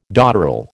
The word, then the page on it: dotterel: American English